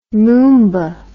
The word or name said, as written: moomba